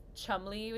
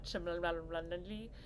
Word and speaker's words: Cholmondeley: this Marchioness of Cholmondeley, which is how you say it, not Cholmondeley, I knew about that.